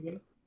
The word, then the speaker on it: Lens: Is it Lens? Is that how you pronounce it? – Yeah. – I feel like it's like R for some reason. FC Copenhagen. – Lens,